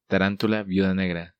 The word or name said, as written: Tarantula viuda negra